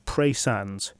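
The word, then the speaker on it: Praa Sands: It's a stone throw from the village and beach of Pray Sands, which is spelt P-R-A-A.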